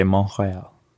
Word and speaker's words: Montreal: And so, in fact, actually, if you speak to like French speakers today, they don't even pronounce the T in Montreal. – Right. – They just say Montreal.